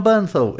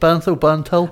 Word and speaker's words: Bernthal: John Bernthal is in, I'm not sure if I've said his name right. – Punisher. – Is it Bernthal? – I think it's Bernatal, isn't it? Right. Yeah, I think so.